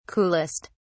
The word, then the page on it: coolest: British English